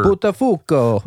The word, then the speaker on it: Buttafuoco: How do you say that? – Sure. – Buttafuoco. – Buttafuoco. But yes.